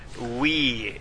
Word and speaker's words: Wii: No, for Mario, you're either going to have to get a Game Boy or the Nintendo Wii. It's pronounced Wii. You know, like when you drink a bunch of PBR and have to take a leak? – That is the dumbest name I've ever heard.